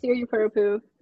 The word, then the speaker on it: Siripurapu: Yeah, Siripurapu, but pretty close, yeah.